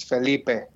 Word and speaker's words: Felipe: In good Latin American, it's Felipe.